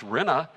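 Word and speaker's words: rinna (רנה): And that word joy is another powerful Hebrew word. It's pronounced rinna.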